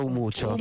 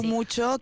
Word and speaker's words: Umuco: Si scrive Umuco, ma si pronuncia Umuccio. – Umuccio, che vuol dire luce.